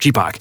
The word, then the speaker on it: Chipak: Chipak. It rhymes with Reebok. Chipak.